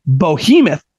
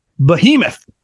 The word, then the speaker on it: behemoth: You say behemoth, and it's actually pronounced behemoth.